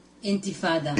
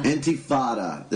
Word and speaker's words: Intifada: This is the third Intifada. – Intifada.